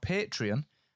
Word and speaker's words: Patreon: Now, Patreon, which is spelled P-A-T-R-E-O-N, Patreon, is a service that I came across first over at New Media Expo over in Las Vegas in 2015.